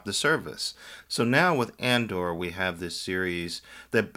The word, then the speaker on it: Andor: And now Andor, am I saying it properly? – You are indeed, Andor, yeah.